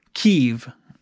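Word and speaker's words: Kyiv: And instead of, we always, you know, pronounce it Kiev, the capital of Ukraine, or we had for many, many years. And now if you listen to the news reporters, they all use the correct pronunciation, Kiev. So that's how Ukrainians pronounce it, and apparently we have finally caught on as a society.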